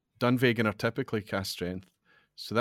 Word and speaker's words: Dunvegan: Okay, so it's Ian McLeod, independently bottled, Rosebank 91, Dunvegan, I think is how you pronounce it. – Dunvegan, yeah.